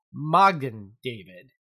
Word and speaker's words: Magen David: Mogan David is a company based out of Westfield, New York, and it's the Yiddish pronunciation of the Hebrew Magen David, which translates to Shield of David, which refers to the Star of David.